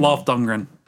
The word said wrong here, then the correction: Dolph Lundgren: Dolph Lundgren.